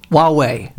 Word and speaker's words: Huawei: Forget about the H. Huawei. – Huawei. The H is silent.